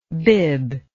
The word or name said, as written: biblike